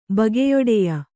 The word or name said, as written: ಬಗೆಯೊಡೆಯ